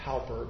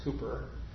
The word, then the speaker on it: Cowper: It's spelled Cowper, but I think it's pronounced Cooper.